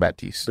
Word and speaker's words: Baptiste: But it's like Batista or something like that. I think it's pronounced Baptiste.